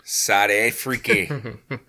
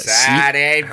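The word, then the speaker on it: Suid-Afrika: Please pronounce it correctly from now on. – Mm. – Sad Afriki. – Yeah, Sad Afriki.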